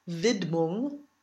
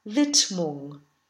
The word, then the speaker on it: Widmung: One thing to note is the pronunciation of the title. The D is not voiced, so it's not Widmung, but Widmung.